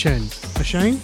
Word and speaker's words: Asiahn: JetBoot Jack with featuring Ashayan. How do you say this name? A-S-I-A-H-N. Ashayan? – Ashayan, yeah.